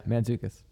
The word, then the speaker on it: Mantzoukas: Mantzoukas? – Yeah, Mantzoukas.